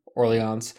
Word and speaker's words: Orleans: Am I saying that right? Yeah, Orleans.